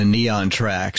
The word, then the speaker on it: Kneontrac: It's a real clever spelling of this device, the Neon Track, spelled KNEONTRAC. Neon rhymes with neon, like neon lights.